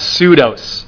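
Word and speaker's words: pseudos (ψεῦδος): The Greek word for falsehood in Ephesians 4, verse 25, is pronounced pseudos, and you might immediately recognize an English word that comes from that, and that's the word pseudo.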